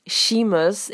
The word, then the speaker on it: Seamus: Et en fait, c'est un nom irlandais. Donc, ça se prononce Seamus et ça s'écrit S-E-A-M-U-S.